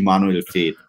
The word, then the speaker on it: Manuel Veth: Yeah, you can find me on Twitter at my name, at Manuel Veth. – I've now realized I've been pronouncing completely wrong and you've corrected me, which is great. – It's actually Manuel Veth, but that's okay.